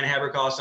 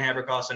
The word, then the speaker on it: Haberkost: So it's just Dan Haberkost on Facebook or same Dan Haberkost on Instagram. Uh, it's spelled phonetically, and that's a K, not a C.